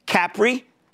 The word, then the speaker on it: Capri: Capri, which, by the way, that's how they pronounce it over there.